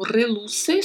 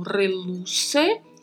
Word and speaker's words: reluce: E quando é utilizado como reluce, só vamos ter um R vibrato, já facilita um pouquinho né, a pronúncia. Então, reluce e o C com som de S, reluce.